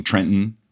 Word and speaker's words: Trenton: You know, growing up in New Jersey and the capital of New Jersey being Trenton, which is spelled T-R-E-N-T-O-N, but it's pronounced T-R-E-N apostrophe I-N. Yeah.